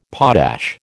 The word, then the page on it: potash: American English